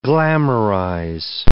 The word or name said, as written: glamorize